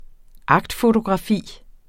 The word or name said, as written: akts